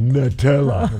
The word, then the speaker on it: Nutella: Someone texted in earlier and said, hey, if you guys want to try something, go with a soft pretzel, dip it in Nutella. – Or I'm Nutella, in for that. – However you pronounce it, I don't even know. – Nut. Emphasis on the nut. – Nutella.